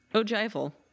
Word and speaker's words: ogival: So architecturally, the elements characteristic of the Angkor Wat style include The Ogival. – Nope. – Ogival. What? – Yep. Yeah, it's pronounced Ogival.